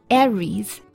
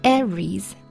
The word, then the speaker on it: Aries: Aries Aries，那它的这个发音呢稍微奇怪一点，就是这个 A 是吧，它发的是 red 那个小 e 的那个音，而不是梅花 i，大家要注意了。